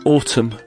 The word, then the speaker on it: autumn: And number 15, autumn, the silent letter is N.